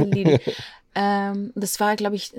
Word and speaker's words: Lille: Ich glaube, das war nach äh, Lille.